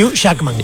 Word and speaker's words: Jackman: J-A-C-K. – Sí, Jackman. – Jackman. – Sí. Hugh Jackman.